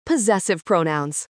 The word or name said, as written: possessive pronouns